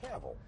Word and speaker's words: Cavill: I also wound up liking Henry Cavill. Is that how you pronounce it? – Cavill. – Cavill. – Yeah, rhymes okay. with travel.